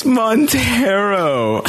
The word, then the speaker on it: Montero: What's called Montreal. – wait, did I kill it? No. Am I saying it wrong? What's the name of the album? – no! – Montana. Morel. – Oh my God, it's Montero.